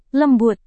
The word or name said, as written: lembut